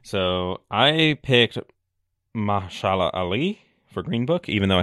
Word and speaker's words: Mahershala Ali: Mahershala Ali, but that is actually wrong. It's Mahershala Ali.